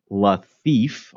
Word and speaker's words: Lafife: The third match is Jean-Pierre Lafife, pronounced like La Thief, although he's a pirate and a thief, and I'm not really sure.